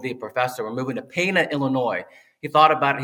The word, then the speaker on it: Pana: It's Pana. Don't feel bad. Pana. We butchered it about 1,000 times when we came here, but it is pronounced Pana.